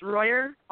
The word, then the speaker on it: Royer: It's Royer, R-O-Y-E-R.